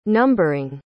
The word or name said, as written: numbering